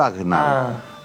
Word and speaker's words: Agnaldo: Sí, como Arnaldo, pero Agnaldo.